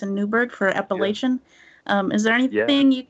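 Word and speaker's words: Apalachin: It took place in 1957, where in the small town of Appalachian, that's how they pronounce it there, and in upstate New York, this state trooper, New York state trooper named Ed Croswell, he sees this really incredible car.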